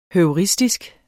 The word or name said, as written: heuristisk